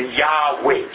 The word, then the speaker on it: Yahweh: 所以后来有学者就加成 YHWEH 啊，所以变成 Yahweh，就是我们今天说的耶和华神。